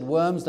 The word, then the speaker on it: Worms: That phrase, sola scriptura, though it was not born in a little city called Worms, or town in Germany, spelt W-O-R-M-S. In English, we would just say Worms, but if you went to Germany and said Worms, they'd look at you strange. Worms is how you say it.